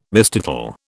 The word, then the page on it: mistitle: British English